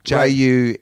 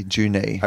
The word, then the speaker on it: Junee: Not like a Jew's knee. Junee. Junee.